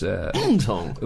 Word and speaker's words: Mtong: And one of the Pirates' most famous players, who's called Kaiser Mtong, I think is pronounced Mtong. – Mtong. It's spelt Mo Twang, but I've been told by South African friends that it's Mtong.